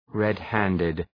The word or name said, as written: red-handed